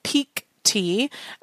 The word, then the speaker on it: Pique Tea: But before we jump into all of those, I do want to thank our sponsor for this week's show, Peak Tea. And that's spelled Q-U-E. So P-I-Q-U-E-T-E-A.